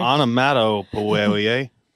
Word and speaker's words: onomatopoeia: Onomatopoeia. – Onomatopoeia. O N O. M A T. – Yep. – O. – Yep. – P. – Yep. – O. – Yep. – N. – No. – Onomatopoeia.